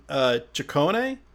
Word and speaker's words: Ciccone: So another guy I wanted to point out, and this is strictly for me, Tony Ciccone, I assume it's pronounced, C-I-C-C-O-N-E, the assistant editor.